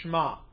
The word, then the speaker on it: Shema: Yes, it is Deuteronomy 6:4-5, and it's, as Mike just said, is also known as the, some people say Shema, but it's normally pronounced Shema. Shema, the E sort of just flows.